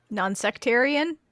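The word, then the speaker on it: nonsectarian: Butler is private but non-secretarian. – Non-sectarian? – Oh, I said that wrong, didn't I? – Non-secretarian.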